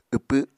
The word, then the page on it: wpw: Apatani